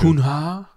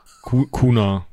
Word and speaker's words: Cunha: Spricht man den so aus? C-U-N-H-A? – Ich, denk, ich denke. Cunha? Cunha. – Cunha. Ein Portugiese. Also ne, eine Insel. Und der Name ist portugiesisch.